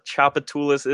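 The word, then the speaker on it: Tchoupitoulas: So for those of y'all not from Louisiana, Chapatulas is in fact spelled with starting with a T. – T-C-H.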